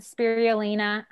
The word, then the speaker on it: spirulina: Earlier that you touched on spirulina.